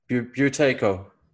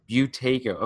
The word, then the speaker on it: Buteyko: But the origin of, how do you say it, Buteyko breathing? – Buteyko. – Buteyko. Okay, I've been saying it wrong for so long.